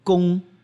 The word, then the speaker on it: Kung Fu: Do you know how Kung Fu is actually pronounced? Gong, Kung Fu, Gong, Gong, Gong, Gong with a G. But you guys write it with a K.